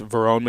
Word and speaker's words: Verone: I mentioned that obviously you stepped on the field and played as a true freshman, but so did Vernon McKinley, Khalif Halos. – It's Verone McKinley and Khalif Halasi. They're kind of hard to pronounce.